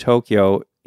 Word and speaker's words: Tokyo: So if you're looking at the word Tokyo in Japanese sounds, you got to, o, kyo, o. So it's actually four.